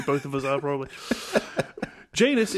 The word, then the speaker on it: Janus: Which looks like it's pronounced Janus, but it is actually Janus, which rhymes with anus, which is why we are both a pain in said anus.